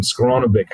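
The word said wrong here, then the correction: Skowronek: How do you say that, JB? I call him Skoronovic. We'll call him Ben Sko. How do we say it? – Skoronic. – Skoronic, that's great.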